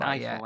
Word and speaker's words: Eiffel: No, it's pronounced like the tower. It's Eiffel, like the tower.